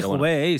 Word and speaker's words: Hubei: El caso es que, por lo visto, en la región eh, China de mm, Hubei. No sé si se pronuncia así. – Sí, Hubei, sí.